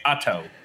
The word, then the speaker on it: Otto: And that's how the locals say it. They say Otto, yeah.